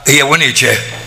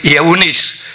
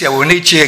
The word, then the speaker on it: Eunice: Mai întâi în bunica ta Lois și în mama ta Eunice. Am pronunțat-o exact cum se scrie. Unii zic Eunice, eu i-am zis Eunice exact așa cum am și citit.